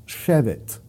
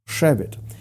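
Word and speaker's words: Shevet (שבת): In fact, there's a very interesting word that, even though it stems from a different root word entirely, it's spelled identically to the word Shabbat, Shin Bet Tav. However, it's pronounced Shevet, Shevet.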